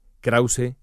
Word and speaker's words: Krauze: Krause, K-R-A-U-Z-E, para quien no tenga claro cómo se escribe mi apellido.